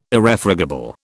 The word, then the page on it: Irrefragable: British English